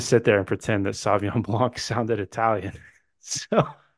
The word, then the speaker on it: Sauvignon: There's a whole extra syllable in there. It's Sauvignon.